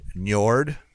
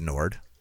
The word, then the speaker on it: Njord: Nord Artisan. – Oh, cool. – Yeah. I think it's Nord. It's NJORD Artisan. And he signed up a few days ago to be on our Patreon.